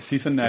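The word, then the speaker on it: Sise-Neg: It's a big, long, ridiculous epic that ends with Sisson Neg. I can never say his name right. Sisson Neg. – Genesis backwards.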